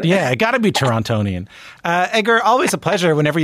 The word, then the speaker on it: Torontonian: a Torontonian. – Well, I felt like I pronounced the second T too hard there.